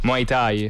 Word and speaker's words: Muay Thai: Muay Thai. – Muay Thai.